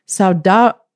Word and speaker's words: saudade: Saudade. S-A-U-D-A-D-E, saudade,